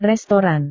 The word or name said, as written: restoran